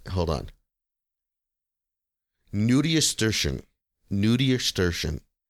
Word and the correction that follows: nudiustertian: Is it like nudiastrition? – No. – Like a pediatrician, but for nude people? – Nope. Nudiestertian. It is spelled N-U-D-I-U-S-T-E-R-T-I-A-N.